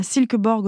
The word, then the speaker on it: Silkeborg: Il y a aussi une exposition au musée Asger Jorn à Silkeborg au Danemark, je le prononce correctement, oui.